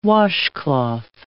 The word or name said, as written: washcloth